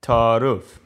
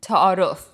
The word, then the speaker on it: ta'arof: Ta'aruf. – Exactly. So formally, the word is pronounced ta'aruf with the stop in the middle of the word, ta'aruf. But colloquially, it's simply pronounced ta'aruf.